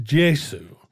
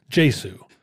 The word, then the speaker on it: Gesù: Actually, in Italian now, it's pronounced Gesù. Gesù.